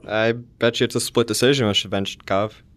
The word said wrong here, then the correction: Shevchenko: With Shevchenko? – Yeah. I can't even pronounce Russian names. I'm half Slavic. – Yeah, that's ridiculous. – Valentina Shevchenko.